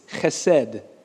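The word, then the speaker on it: chesed (חסד): This word that's has all these connotations to it in Hebrew it's chesed, which I'm not going to do that again. If you want to spell it, H-E or C-H-E-S-E-D.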